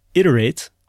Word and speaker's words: iterate: But in any case, it is pronounced iterate with a short I, and it is an important part of engineering.